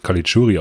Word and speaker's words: Caligiuri: Ja, er wird Caligiuri ausgesprochen.